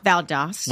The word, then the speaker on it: Valdosta: Is that how you say it? Valdosta? Valdosta? – Valdosta.